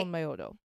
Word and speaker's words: Onmyōdō: Onmyodo. – Yeah. It's spelled O-N-M-Y, O with like a line above it. – Okay. – D-O with a line above it. – Okay. – Onmyodo.